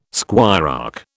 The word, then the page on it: squirearch: British English